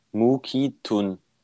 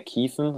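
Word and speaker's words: McKethan: Und da steht pronounced Mukitun. Mukitun. Nicht mehr Kiefen, sondern Mukitun.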